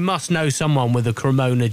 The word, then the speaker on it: Cremona: Mikey Mills, I bet I've said it's not a Serramonna, is it? Well, how do you pronounce it? – It is a Cremona J.